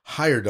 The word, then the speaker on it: Heyerdahl: Heyerdahl. – Is that right? – Yeah, I think it's Heyerdahl. Heyerdahl is the way we've always pronounced it.